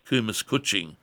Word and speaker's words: kumis kucing: J-A-V-A, Java. Java is a part of Indonesia. The herb... – Java kidney tea. – It's also known as kumis kucing, which is the Indonesian or Malaysian way of pronouncing it,